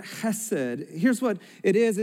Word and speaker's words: chesed: Mercy, this word mercy here is actually the Hebrew word chesed. And you got to have the in there to say it right. So go ahead with me. Can you say chesed?